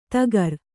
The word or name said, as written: ಧಕಾರ